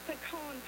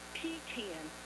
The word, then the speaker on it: pecan: It's pecan pie, not pecan. A pecan is a porta potty, not something you eat.